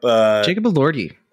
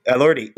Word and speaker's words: Elordi: But I do really like, I don't know how to pronounce his last name, but Jacob Elordi. – Elordi, who I think is really good in this.